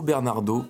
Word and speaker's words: Bernardo: Le violoniste capverdien Travadigna, c'était le morceau Au Bernardo, prononcé bien à la française comme on aime, et ça date de 1986.